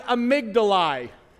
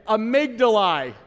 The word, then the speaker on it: amygdalae: Lord, I thank you for my amygdalae. Let's pronounce that funny word again. Amygdalae.